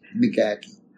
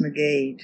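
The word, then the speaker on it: McGaughey: How do you spell that last name? – G-A-U-G-H-E-Y. – I heard that M-C, pronounced. McGackie. – I've heard it pronounced McGee, too. – Well, we said McGackie.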